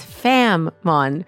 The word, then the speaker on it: Fam Mon: It's Fam Mon, but it's a little heavier on the tongue. So I think you pronounce the M pretty heavily, which is nice.